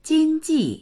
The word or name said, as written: jing1ji4